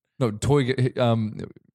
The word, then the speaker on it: Toyger: Sort of pronounce it like you were a villain in Austin Powers. – Please demonstrate. – Toyger.